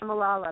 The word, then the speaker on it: Malala: So we have a couple songs in here, The Rose Is Gonna Ride, and we are, let's see if I'm saying it right, Malala or Malala? – Yep. – I'm not. – We are Malala, about Malala Yousafzai.